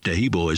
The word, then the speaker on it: Taheebo: Tehebo is spelled T-A-H-E-E-B-O.